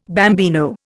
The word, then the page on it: bambino: Indian